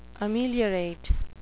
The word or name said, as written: ameliorate